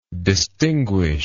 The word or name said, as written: distinguishment